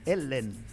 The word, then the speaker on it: Helene: É sócio do Florian, é sócio da Helene, que se pronuncia como mesmo? – Helene, né?